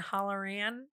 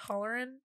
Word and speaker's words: Halloran: I've heard it pronounced Halloran and Halloran. I'm going to go with Halloran because it sounds like hootin' and hollerin.